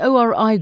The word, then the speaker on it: ORI: A director of the ORI group, Abel Manyike, took the stand this morning. Why did he testify? – Okay, so Ori, as they as they they, they, they, they pronounce it, he was one of the subcontractors.